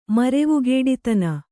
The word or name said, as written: ಮರೆವುಗೇಡಿತನ